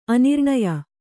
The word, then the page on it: ಆನೃಣ್ಯ: ānřṇya